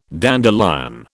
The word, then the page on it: dandelion: British English